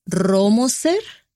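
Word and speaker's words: Rohrmoser: Como ya mencionamos, estuvimos en San José y específicamente estuvimos en el área de Romoser, que se escribe súper raro, pero así lo pronuncia la gente, Romoser.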